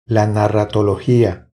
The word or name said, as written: La narratologia